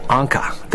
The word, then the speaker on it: Encke: comet Encke. That's E-N-C-K-E. I finally learned how to pronounce it from my friends from Germany.